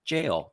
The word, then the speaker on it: Gaol: Because jail is spelt G-A-O-L. And so I was going to be like, oh, it's goal or gale or something like that. And then I listened to the pronunciation. And it's like jail.